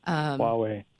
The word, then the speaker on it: Huawei: And is it the company, I don't know how to pronounce it. – Huawei.